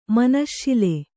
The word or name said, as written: ಮನಶ್ಶಿಲೆ